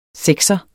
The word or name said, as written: sekser